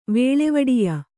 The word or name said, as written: ವೇಳೆವಡಿಯ